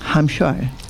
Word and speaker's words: Hampshire: Hampshire.